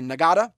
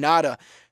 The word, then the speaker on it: Ngata: It's actually spelled, his last name is spelled N-G-A-T-A. So some people say Nagata, but it's actually Ngata. The G is silent there.